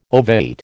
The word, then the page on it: ovate: American English